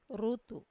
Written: rutu

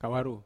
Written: kawaru

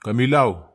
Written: kamilau

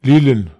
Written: lilen

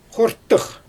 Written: xortɯx

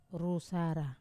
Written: rusara

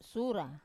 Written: sura

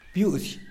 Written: byuuzy

